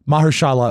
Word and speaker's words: Mahershala: It's M-A-H-E-R-S-H-A-L-A, I'm going to butcher this. – S-H-A-L-A, Ali. – Hold on a second. – Mahershala Ali. – Okay. – I see. – Will you get it and pronounce it correctly, please? Thank you. – I would say Mahershala Ali. I think he got it right.